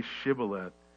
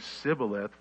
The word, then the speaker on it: Shibboleth: If he said no, then he would say to him, "Then say Shibboleth." And he would say Sibboleth, for he could not pronounce it right.